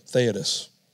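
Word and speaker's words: Theudas: Caden told me how to pronounce this first guy's name, Theodos.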